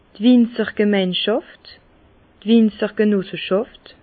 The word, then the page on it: d' Winzergemeinschàft, Winzergenosseschàft: Bas Rhin; Herrlisheim